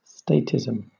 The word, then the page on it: statism: British English